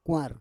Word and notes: kwar: Roon

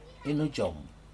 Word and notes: inhujum: Aneityum

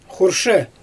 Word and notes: xurʃɛ: Xyzyl

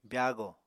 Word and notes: byaago: Apatani

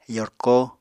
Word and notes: yorko: apatani

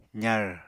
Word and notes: zar: apatani

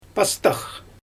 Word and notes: pastax: Xyzyl

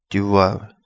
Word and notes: Di Wawa: Kapingamarangi

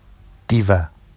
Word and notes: diva: Vafsi

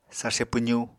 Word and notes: sarse pwzu: Apatani

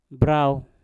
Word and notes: brauw: mpur

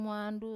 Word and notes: muandu: wamesa

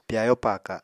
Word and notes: pyayo paaka: Apatani

